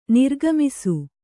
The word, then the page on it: ನಿರ್ಗಮಿಸು: kannada